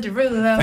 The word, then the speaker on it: Derulo: So you did pronounce it wrong. – Jason Derulo. – Thank you so much.